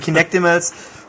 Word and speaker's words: Kinectimals: Äh, irgendwo war auch zu hören, Teile von äh, Milo sind in Kin äh, Kinect Animals drin. Also was oder? Kinectimals? – Kinectimals. – Äh, okay, Kinectimals. Jetzt habe ich es richtig gesagt, Kinectimals. Oder? Äh, jetzt war es richtig, ja.